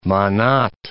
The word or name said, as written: manat